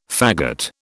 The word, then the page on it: faggot: British English